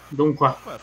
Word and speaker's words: Donghua: G-H-U-A. – Tá. – D-O-G-H-U-A. – Então seria. Então, é porque seria meio que um Donghua. É tipo um R meio neutro, por assim dizer. – Sim, o G é mudo, né?